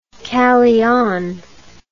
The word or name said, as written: calean